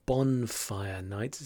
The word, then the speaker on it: bonfire night: Just a quick note here to confirm the pronunciation of this word, bonfire night. It's a bonfire night, not boon or bun, which you probably heard from both Martin and me, but it's bonfire night.